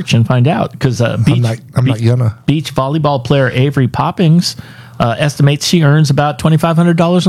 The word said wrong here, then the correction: Popinga: I thought it was Poppings, but it's Popinga. It's popping with an A instead of an S at the end. Avery Popinga.